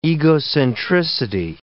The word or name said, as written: egocentricity